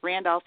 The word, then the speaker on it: Randolph: Randolph is spelled R-A-N-D-O-L-P-H.